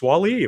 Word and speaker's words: Wall-E: Oh, yes, Wall-E, of course.